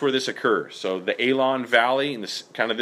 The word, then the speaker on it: Aijalon: You can see on the map, A-I-J-A-L-O-N, okay? Pronounced Aalon Valley, okay?